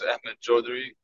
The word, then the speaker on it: Ahmed Chaudhry: My name is Ahmed Chaudhry.